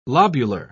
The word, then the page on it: lobular: British English